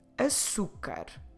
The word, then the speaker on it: açúcar: Do you know the word for sugar? Açúcar. A-su-car.